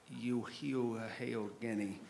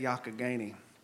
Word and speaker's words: Youghiogheny: Y-O-U-G-I-H-G-H-N-E-Y, which looks like it would be pronounced Youghiogheny, which is approximately the way I did pronounce the word for the audiobook until the publisher got back to me and said, was this guy smoking something funny in the audio booth? And we learned how to say it, Youghiogheny.